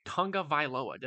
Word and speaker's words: Tagovailoa: Quarterbacks, Patrick Mahomes, Lamar Jackson, Tua Tagovailoa. Did I pronounce that correctly? – Yes, sir, you did.